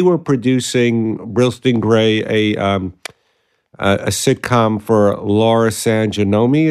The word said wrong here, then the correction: San Giacomo: is that how you say it? – Yeah, San Giacomo.